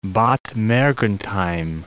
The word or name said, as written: Bad Mergentheim